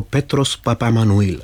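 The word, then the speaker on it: Petros Papaemmanouil: Pane řediteli, než se zeptám na festival jako takový, jaký je to s výslovností toho pěvce? – Ano, je to Petros Papamanuil.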